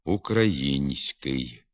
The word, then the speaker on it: український: Візьмімо слово «український». У ньому за допомогою м'якого знака показується пом'якшена вимова тільки звука «с», а м'якшення звука «н» не позначається, хоч він вимовляється також пом'якшено «український». Така невідповідність орфографії слова його вимові нерідко призводить до орфоепічних помилок.